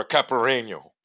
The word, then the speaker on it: caipirinha: Everything about this album, even the cover, just screams, have a martini, or a caipirinha. Is that how you pronounce it? – Yes. – Yeah.